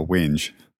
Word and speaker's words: whinge: They didn't really mean that, but they really were having a whinge. – What is it? W-H-I-N-G-E? – Yes. – I always thought that was just an alternate spelling of wine, but you actually pronounce it whinge.